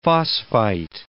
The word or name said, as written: phosphite